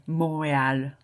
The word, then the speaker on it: Montréal: Yeah, I know in Montreal they say Montréal. I've heard that. I think that's how it's pronounced there. In France, I would kind of, in French, my natural instinct would say à Montréal, Montréal. – Mine too. – Montréal, Montréal. Yeah, probably I would drop the T just out of instinct, I think.